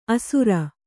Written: ಆಸರ

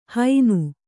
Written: ಹೈನು

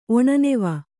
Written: ಒಣನೆವ

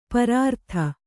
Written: ಪರಾರ್ಧ

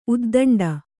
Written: ಉದ್ದಂಡ

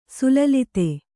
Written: ಸಲ್ಲಲಿತ

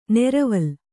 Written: ನೆರವಲ

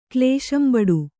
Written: ಕ್ಲೇಶಂಬಡು

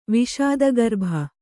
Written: ವಿಷಾದಗರ್ಭ

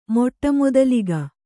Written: ಮೊಟ್ಟಮೊದಲಿಗ

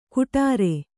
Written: ಗುಡಾರೆ

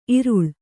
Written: ಈರಳ್ಳೆ

